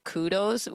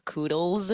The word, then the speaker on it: kudos: His most famous one is kudos, which he actually pronounces koodles with the L-E-S, and that's the best one so far.